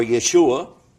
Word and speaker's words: Yeshua: His name in Hebrew is pronounced Isha or Yeshua, and it's Isa in Arabic.